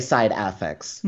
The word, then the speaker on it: Side Affects: Yeah, I say side affects, even though a lot of people are just casually calling it side effects, and I keep wanting to correct folks, but that's impossible to do at some point.